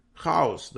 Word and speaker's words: хаос: Поэтому и говорят, что Трамп и его управление – это хаос. Хаос, да, как тут ударение ставят на слово. Хаос. Вот, это хаос.